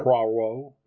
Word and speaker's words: Poirot: If you like Poirot, I can't roll my R's the way to say it properly. If you like Hercule Poirot. – Yeah. – Poirot.